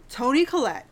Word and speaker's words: Collette: Daniel Craig, Chris Evans, Ana de Armas, Jamie Lee Curtis, your girl, Michael Shannon, Don Johnson, Tony Colletti, and then a bunch of other names that I'm not going to try and pronounce. – Let me tell you, and Tony Collette, she is one of my most favorite actresses.